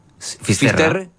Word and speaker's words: Finisterre: Finisterre.